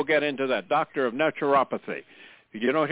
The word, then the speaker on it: naturopathy: And then really what it is you do as a doctor of neuropathy. Am I pronouncing it right? Others say neuropathy. – Naturopathy. – Naturopathy.